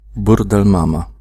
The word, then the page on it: burdelmama: /ˈbur.d̪ɛl.ˌma.ma/